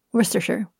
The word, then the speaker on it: Worcestershire: W-O-R-C-E-S-T-E-R-S-H-I-R-E. – Worcester. – What about the S-H-I-R-E at the end? – There's a sheer at the end. – Worcestershire. Worcestershire. – Did you listen? – That is correct. Worcestershire.